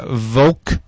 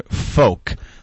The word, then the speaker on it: Volk: Sylvia says, FYI, the German word Volk is pronounced like the English word folk. The German V makes an F sound, whereas the German folk. W makes the English V sound.